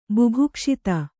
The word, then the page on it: ಬುಭುಕ್ಷಿತ: bubhukṣita